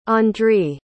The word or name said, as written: Andriy